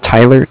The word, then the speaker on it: Tyler: My name is Tyler, T-Y-L-E-R, and I'm calling with Cardiff. Like I said, it's spelled C-A-R-D-I-F-F, as in Frank.